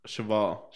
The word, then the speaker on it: cheval: Horse in French. – Yeah, Chevelle. – Oh, duh. Cheval.